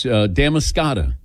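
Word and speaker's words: Damariscotta: Yes, Damascata is the correct pronunciation.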